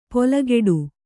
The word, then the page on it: ಪೊಲಗೆಡು: polageḍu